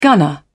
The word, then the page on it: gunner: British English